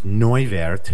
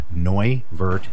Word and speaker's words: Neuwirth: So when I think about the whole idea of my upbringing, so my father, my name is Neuwirth. It's pronounced Neu, N-E-U, and then W-I-R-T is Vert. Neuwirth is what it, how it's pronounced. It's Austrian.